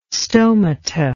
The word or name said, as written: stomata